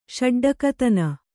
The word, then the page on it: ಷಡ್ಡಕತನ: Kannada